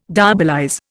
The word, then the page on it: diabolize: Indian